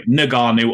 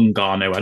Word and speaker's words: Ngannou: So I have got down Francis, and it depends how you want to pronounce his last name, Ngannou or Ngannou. I don't think it really matters. Everyone says it's either way.